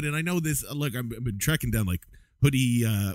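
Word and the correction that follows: Hudl: What? How do you pronounce that? HUDI. That whatever that thing is. – The huddle film.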